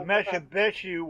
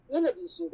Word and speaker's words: Inabishu: And actually, it's Inabishu. That's the pronunciation that I was given by linguists in the Native American storyteller communities. It's actually pronounced Inabishu because the spellings and the letters used don't always match up to how it's pronounced.